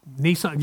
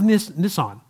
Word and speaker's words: Nisan: That month happened to be the month, and I don't know that I'll pronounce it right, but the month Nisan, N-I-S-A-N, I believe. The month Nisan. And the 10th day. Nisan, yeah, that's a car though, Nisan. So I didn't want to say it like that.